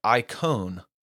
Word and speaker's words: eikon: Well, this idea comes from the Greek translation of the Old Testament, the Septuagint, and there the word image is the Greek word icon. And so it's spelled E-I-K-O-N, icon.